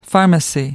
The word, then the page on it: pharmacy: American English